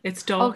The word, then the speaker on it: Dalkey: And The Weight of Love was recently nominated for the Dalkey Literature Awards for Novel of the Year. – How did you say that? – Dalkey? – Dalkey. – D-A-L. – Yeah, you don't Dalkey. Say the L. It's Dalkey.